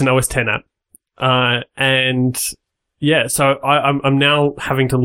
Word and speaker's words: OS X: So just given that we were all decided it's polite to correct people's pronunciations, so you don't do it for years. – OS 10. – Yeah, so OS 10.